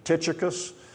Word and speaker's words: Tychicus: In Alabama, we call him Tychicus. I think the proper pronunciation is Tychicus. You'll hear me say Tychicus. That's just the way I was raised to say it. But it's Tychicus.